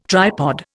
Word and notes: tripod: Indian

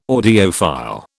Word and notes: audiophile: British English

